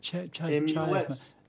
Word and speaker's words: chiasmus: It's chiasmus. – Chiasmus.